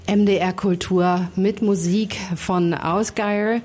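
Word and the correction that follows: Ásgeir: Wir spielen wechselnd, ich glaube, so spricht man den. – Ich glaube, der heißt Asgeir. – Asgeir. – Ist Isländer.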